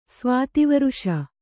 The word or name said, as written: ಸ್ವಾತಿವರ್ಷ